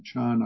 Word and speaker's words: Chan: Actually, both Zen and Chan are Chinese pronunciations, but the primary Chinese form of pronouncing the word is Chan.